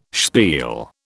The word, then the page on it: spiel: British English